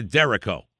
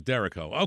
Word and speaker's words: derecho: Could you please explain the difference between a microburst and a derecho? – Derecho. Oh, okay. – Did I say it wrong? – We've said those. Remember, we've talked about those, the derechos? – Oh, derecho. I thought it was derecho.